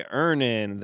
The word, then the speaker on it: Earnin: Today's episode is brought to you by Earnin. That's like earn in. That's how it's pronounced.